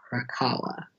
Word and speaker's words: Arakawa: Arakawa, yes.